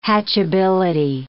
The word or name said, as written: hatchability